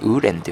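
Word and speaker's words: 无人: え発音ですね、ウーレンというふうに言います。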